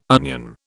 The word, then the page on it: onion: British English